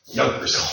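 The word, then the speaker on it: Junkers: In English, in Israel, they call it a Yonkers. Yonkers. A Yonkers. – which is spelled with a J.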